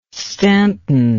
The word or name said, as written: staunton